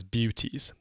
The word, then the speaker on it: BUTAS: The acronym is BUTAS, pronounced, if you want to remember it more easily, as BEWTAS. That's BEWTAS. Not the most memorable acronym ever, I must admit, but it does cover everything, and so it is hugely useful.